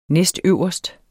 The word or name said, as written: næstøverst